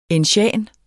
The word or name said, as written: ensian